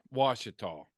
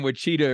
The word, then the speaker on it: Washita: Is that how you say that? You're from Oklahoma. – Washita. – Okay. See, so I've been mispronouncing it for years because I'm not from there. – I was going to let him keep saying Wachita or whatever it was.